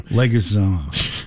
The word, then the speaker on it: Leguizamo: Leguizamo.